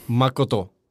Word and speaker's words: Makoto: Jag har ju försvenskat mitt uttal när jag presenterar mig här, för att jag vet att folk kommer inte fixa det. Liksom. – Men hur ska det uttalas då? – Makoto. Ja, det liksom bryter ju bara mot hela det svenska språket om du ska liksom prata någorlunda stringent.